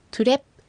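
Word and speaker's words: トゥレㇷ゚: はい、次トゥレップ、ここ最後小さいプの発音ですので、上唇と下唇が閉じていることを意識して発音してください。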